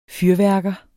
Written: fyrværker

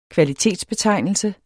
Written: kvalitetsbetegnelse

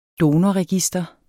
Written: donorregister

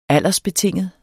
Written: aldersbetinget

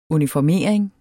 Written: uniformering

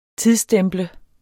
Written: tidsstemple

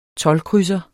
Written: toldkrydser

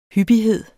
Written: hyppighed

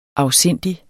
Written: afsindig

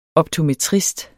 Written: optometrist

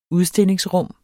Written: udstillingsrum

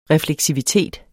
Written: refleksivitet

